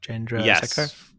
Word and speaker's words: Chandrasekhar: Chandrasekhar.